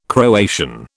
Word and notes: Croatian: British English

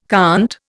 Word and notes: conte: Indian

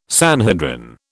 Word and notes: Sanhedrin: British English

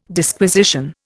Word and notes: Disquisition: Indian

